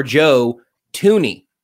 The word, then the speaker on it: Joe Thuney: The first thing I have to do, because it's been absolutely beaten into me these last couple of days, is that I have to change your Joe Thuney, because that's how it's pronounced, fair.